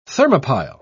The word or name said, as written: thermopile